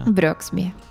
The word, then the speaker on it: Brooksby: E dovesse vincere a un bel secondo turno col vincente Brooksby o Brooksby, come si pronuncia? Brooksby. Brooksby, Brooksby. Lajovic.